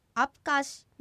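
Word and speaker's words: アプカㇱ: アプカシの最後のシは子音だけで母音はありません。母音を発音してアプカシにならないように注意してください。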